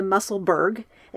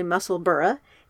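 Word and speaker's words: Musselburgh: So what I was calling the Musselburg is actually Musselburra,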